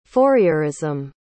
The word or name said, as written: Fourierism